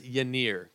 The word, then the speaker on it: Yennier: I believe it's Yennier Cano. That's how you pronounce it. Y-E-N-N-I-E-R Cano, if there's any confusion on who I'm talking about there.